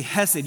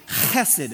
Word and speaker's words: Chesed: And so kids, you don't just say Hesed, you say Chesed. Can you say Chesed? Chesed. If you're not coughing something up from deep down inside of you, you're not saying it right.